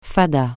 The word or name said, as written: fada